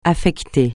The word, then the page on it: affecter: French